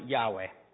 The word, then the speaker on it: Yahweh: The actual name there of God is YHWH. We pronounce it Yahweh. Okay? So I want you guys to picture in your mind, my soul praise Yahweh.